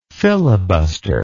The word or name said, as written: filibusterous